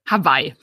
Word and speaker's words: Hawaii: Spricht man eigentlich Hawaii aus oder Hawaii? Hawaii, ganz normal Hawaii.